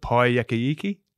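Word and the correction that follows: Paekākāriki: Pai Kakariki.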